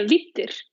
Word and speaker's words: Vidir: Let's talk a little bit about your piece, and you may have to help me with the pronunciation, Vidir, if I said it correctly. – Yeah, Vidir. – Vidir, okay. – Yeah, perfect.